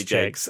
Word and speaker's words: Jacks: It's spelt like Jacks, but it's definitely Jakes. – Okay, fair enough. Alex Jakes then.